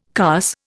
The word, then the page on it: carse: Indian